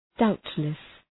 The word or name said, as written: doubtless